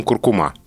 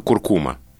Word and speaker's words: куркума: С тех пор, как я узнал, что словари настаивают именно на этом ударении, и ни на каком другом, это слово, которое все живые нормальные люди произносят с ударением куркума. – Да. – Да, а словари настаивают на том, что правильно только куркума.